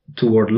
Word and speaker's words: toward: Dus toward love is dan weer, dan is de klemtoon op de laatste lettergreep.